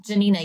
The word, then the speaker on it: Janina: Janina, yeah.